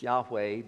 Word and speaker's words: Yahweh: It's been pronounced a variety of ways. Jehovah is sort of the older pronunciation. We sang a hymn that had Jehovah in that hymn. The, the more modern tr- pronunciation is, is Yahweh, but the important thing is not how we pronounce it.